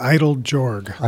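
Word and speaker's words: Eiteljorg: It's called the Idle-Jorg. – Idle-Jorg, thank you.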